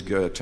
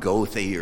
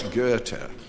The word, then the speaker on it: Goethe: You know, it was the German philosopher Goethe, and by the way, it is Goethe, it's not Goethe or some way that most Americans pronounce it. He'd never recognize it. It's Goethe.